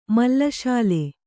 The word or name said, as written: ಮಲ್ಲಶಾಲೆ